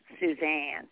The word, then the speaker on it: Suzanne: The way you say my name, my grandmother used to say my name like that, Suzanne. – Oh, really? That's how my mother used to. – She put the emphasis on that.